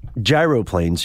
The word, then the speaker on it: gyroplane: The the Gyroplanes, and you are correct, Scott, that is the correct pronunciation.